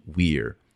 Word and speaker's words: vir: It's related to the Latin word vir, or if you use the classical pronunciation, vir. V-I-R, it means man.